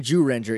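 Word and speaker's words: Zyuranger: We're saying Jurenja, even though it's spelled like Z-Ranger. – Right. – Because that's how it's pronounced.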